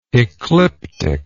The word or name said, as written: ecliptically